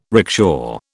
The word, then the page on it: rickshaw: British English